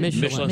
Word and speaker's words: Michelin: I don't think it's like, you know, like Michelin star. Is that how you say it? – Michelin star.